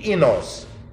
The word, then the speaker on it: oinos: O-I- N-O-S. The diphthong O-I is pronounced E, enos.